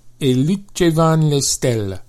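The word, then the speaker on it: E lucevan le stelle: E Luce Van Le Stelle.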